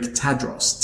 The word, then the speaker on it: Tadros: So B-A-S-S. And my surname, phonetically spelled, sounds very Greek. Tadros. T-A-D-R-O-S.